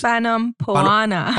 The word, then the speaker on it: Fanompoana: She would follow the tradition of something called Fanopanoma, which is Fanompoana. – Fanompoana. Yeah, I'm definitely butchering that.